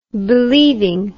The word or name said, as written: believingly